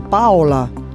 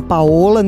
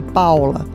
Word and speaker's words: Paola: Então, meu nome vem da, da minha avó, que era italiana, e ela escolheu esse nome para mim, então escreve com O, mas pronuncia Paula, tipo como os italianos falam Paula. É um O que não é Paola, nem é um U como do Brasil, então é Paula. Pronuncia assim, Paula.